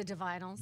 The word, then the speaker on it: Divinyls: It's the Divinyls.